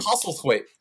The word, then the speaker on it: Postlethwaite: Postlethwaite.